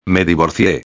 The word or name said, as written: me divorcie